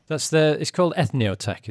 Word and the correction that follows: Entheotech: is that right? – Yeah, Entheotech. – Oh, yeah. – Entheotech, my bad.